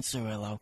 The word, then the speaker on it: Cirillo: You can follow me on Twitter at Justin Cirillo. Last name is spelled C-I-R-I-L-L-O.